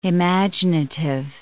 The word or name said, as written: imaginative